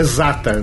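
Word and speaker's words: Zaatar: É Zatar, né? – Exato. – A pronúncia é essa, né? – Sim.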